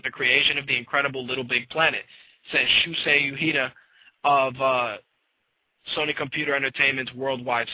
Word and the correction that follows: Shuhei Yoshida: Actually, I pronounced his name wrong. It's Shuhei Yoshida.